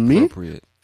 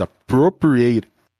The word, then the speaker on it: appropriate: And it's like, I know it's spelled A-T-E, so you think eight, but when we're speaking naturally, it sounds more like it, appropriate. You see? – Appropriate. That's no eight, it's it.